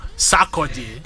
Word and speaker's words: Sarkodie: Sarkodie will come and fine you for how you are pronouncing his name. – I'm sorry, I would never intentionally butcher the name, but Sarkodie. – Yeah, okay? That's better.